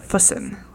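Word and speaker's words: Füssen: It's spelled F-U-S-S-E-N. And the guy at the pit stop says, like, Fussen.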